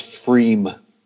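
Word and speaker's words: Pfriem: Yeah, I think it's pronounced Freem. – Just, P. Freem. Just, oh, just no P? Okay. Just, yeah, P is silent, so Freem. – Oh, Freem. Sorry, P. Freem. It's Freem. But look at that appearance.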